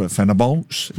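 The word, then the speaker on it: Fenerbahçe: Love the Fenerbahce. It's Turkish, Fenerbahce. – Oh, whoops. – But I love the pronunciation. Very French.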